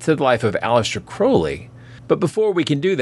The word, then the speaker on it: Crowley: Also, just a quick note, I believe his name is pronounced Crowley, as Robert Anton Wilson constantly reminds me. It's supposed to rhyme with holy.